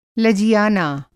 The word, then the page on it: लजियाना: Hindi